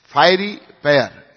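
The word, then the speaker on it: fiery pyre: and then built a fairy pair. Excuse my, pronunciation. Fiery pair, is it right? Yeah.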